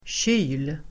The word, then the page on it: kyl: [tjy:l]